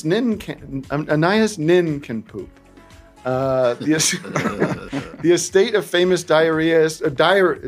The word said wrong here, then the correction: diarist: it should be diarist. What do you call it when you write a diary and they put it out? A diarist? Diarist.